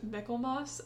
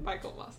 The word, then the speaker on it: Michaelmas: It's actually pronounced Michaelmas, and I only ever read it, so I always say it wrong, but I think it's actually pronounced Michaelmas. I read it Michaelmas.